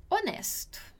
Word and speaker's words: onesto: Legal aqui que a gente tem onesto no italiano e onesto no português, que tem a mesma pronúncia, são palavras muito parecidas, mas a escrita, a grafia é diferente.